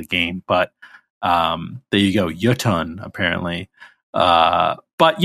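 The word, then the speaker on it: Jötunn: I thought there was also, by the way, I did look up how to pronounce Jotun, as we were saying it. Apparently it's a Icelandic word. And it's actually apparently pronounced Jotun.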